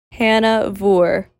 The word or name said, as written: Hannah Voore